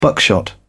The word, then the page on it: buckshot: British English